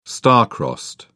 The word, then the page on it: star crossed: British English